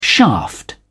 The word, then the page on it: shaft: British English